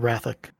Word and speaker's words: Wrathoc: I've been saying your name wrong all the time. Are you Wrathoc? – Usually just Wrath or Wrathoc. Either way.